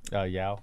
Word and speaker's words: Yeoh: Is that how you say her name? Yeoh? – Yao. – Yao.